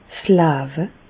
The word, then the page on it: 's Lawe: Bas-Rhin; Reichshoffen